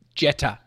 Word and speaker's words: Jeter: I've butchered his name. Derek somebody, though. Jeter. J-E-T-E-R. Derek Jeter.